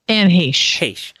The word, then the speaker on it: Anne Heche: Anne Heche.